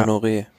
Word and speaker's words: Honoré: Wout van Art auf Platz Nummer 3 von Team Jumbo, der neue Mannschaftskollege Julian Alaphilippe, Mikkel, wie spricht man aus? Mikkel Fröhlich Honor. Pascal Enkhorn. Fröhlich, ja, ja, Honoré.